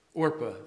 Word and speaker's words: Orpah: Naomi told her, her husband, Naomi's husband died, her sons died who were married to Ruth and Oprah. Not Oprah. How do you say her name? Orpah, thank you. I do that. Looks like Oprah when you read it, don't?